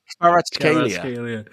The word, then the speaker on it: Kvaratskhelia: It's Kvaratskhelia.